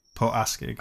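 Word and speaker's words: Port Askaig: Port Eskraig, is it? – Port Askeig. – Askeig.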